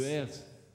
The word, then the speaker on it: hertz: Il doit y avoir une relation entre le hertz et le décibel. – Le S, vous appelez quoi? – Le hertz. – Ah, le hertz. Ouais. – J'ai mal prononcé.